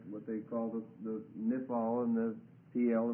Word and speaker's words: Qal: A given verb can occur in what they call the call form. It's spelled Q-A-L, which means the simple form.